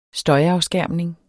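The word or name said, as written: støjafskærmning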